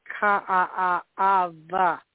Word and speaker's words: Kaaawa: Like a city would be, I lived here in this place, would be Ka'a'ava. Even though it's spelled K-A-A-A-W-A, the W's are V's, you know.